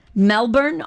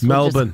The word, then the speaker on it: Melbourne: Number 8, Melbourne, Australia. Oh, I'm saying it wrong. Chris Melbourne.